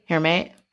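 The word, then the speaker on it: Hermès: Hermes? Am I saying that right? – That sounds right.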